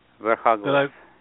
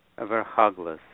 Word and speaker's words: Verkhoglaz: Verkhoglaz. – Say it again. – Verkhoglaz.